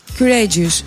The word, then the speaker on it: courageous: Κουράτζιους, τι είναι κουράτζιους? – Αυτός που κάνει κουραδάκι. – Που ενθαρρύνει. – Κουραδίνια. – Και πώς διαβάζεται κουράτζιους? – Κουρέτζιους. – Κουρέτζιους και.